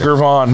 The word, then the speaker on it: Gervon: So now we got, am I saying this right? Is it Gervin or Gervin? – Gervon Dexter. – Gervon. Man, I'm terrible.